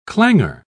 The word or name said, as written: clangor